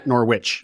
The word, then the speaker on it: Norwich: How, aside from the family stuff and the remote, the Geneva to Norwich, is it pronounced Norwich or Norwich? – Yeah, Norwich. Norwich. Okay. Norwich. It's a silent, the W.